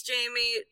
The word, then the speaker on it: Jaime: Since I was a kid, folks would always spell it different, or they'd like substitute teachers would be like Jaime, and I was like, it's Jamie, it's cool.